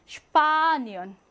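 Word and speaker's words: Spanien: 슈파, 여기 강세가 오기 때문에 슈파니언 이렇게 발음하고요.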